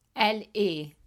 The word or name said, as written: elle est